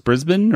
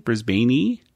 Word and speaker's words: Brisbane: Is it just pronounced Brisbane or Brisbane? – I think it's Brisbane. – And he was just mispronouncing it because he said put an E at the end, Brisbaney.